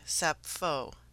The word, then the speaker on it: Sappho: My journey began in Athens, where I entered a bookstore in the Plaka and inquired about Sappho, pronouncing her name in the Greek way, Sappho.